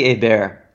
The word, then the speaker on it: Hebert: I pronounced your name Hebert, which is how it would be pronounced to many Americans, I would assume. But the correct pronunciation, help me out, Ben, is? – So it's actually Hebert. And okay. French, no? – It's French-Canadian, actually.